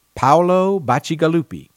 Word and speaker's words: Bacigalupi: Paolo Bacigalupi.